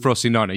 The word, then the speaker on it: Frosinone: Perugia, and then went to Frosinone. Yeah. – He is with Frosinone.